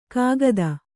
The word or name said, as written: ಕಾಗದ